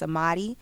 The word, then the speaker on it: Amadi: So that's Amadi.